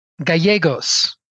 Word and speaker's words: Gallegos: It's a Spanish name, and so just going forward, when you see two L's together in Spanish, it's always pronounced as a Y. – Yes. – So, Gallegos.